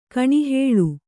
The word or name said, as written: ಕೆನೆಹಾಲು